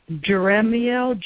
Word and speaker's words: Jeremiel: All right, let me get the correct pronunciation. Some of these just don't roll easy off the tongue. Jeremiel, Jeremiel. Jeremiel.